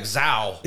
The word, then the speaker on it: Zao: how you pronounce it? – That's how I've always pronounced it. – Oh, I'm on this show like Zao. – Yeah, I know. Every time you said it, I'm like, that's fine. It's an outlier.